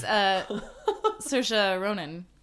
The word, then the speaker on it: Saoirse Ronan: No, it was Saoirse Ronan. Did I say her name right? – You did. – You did, yeah.